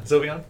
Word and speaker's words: Sylveon: But they brought out Sylveon. Sylveon's the new one. Attacker. – Yeah, when you are pronouncing correctly. – Yeah, Sylveon.